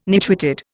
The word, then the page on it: nitwitted: Indian